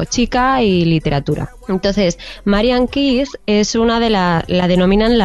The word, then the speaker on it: Keyes: Bueno, se escribe Marianne Keyes, pero se pronuncia Keyes.